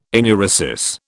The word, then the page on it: enuresis: British English